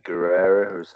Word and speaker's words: Guerrero: Yeah, and he was also quite affected, wasn't he, by the death of Eddie Guerrero? Have I pronounced the surname right? Yeah. Is it? Yeah.